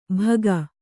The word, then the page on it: ಭಗ: Kannada